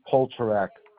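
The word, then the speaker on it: Poltorak: It's Rodney Polterak.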